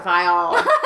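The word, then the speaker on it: Viall: Vial. – I think is how they've been saying it. – It's like Viagra. Vial.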